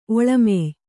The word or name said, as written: ಒಳಮೆಯ್